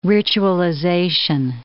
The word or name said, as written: ritualization